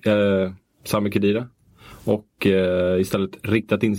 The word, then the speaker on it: Sami Khedira: värjer man Sami Khedira som vi fått lära oss av mm. Nemrut Kurt att han ska uttalas.